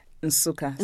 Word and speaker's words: Nsukka: Nsukka. So the N is hummed.